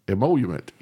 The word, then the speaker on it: emolument: He wrote about this in his Federalist Papers, one of his Federalist Papers, about the whole emolument. How do you say that? – Emolument.